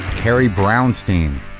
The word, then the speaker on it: Carrie Brownstein: Carrie Brownstein.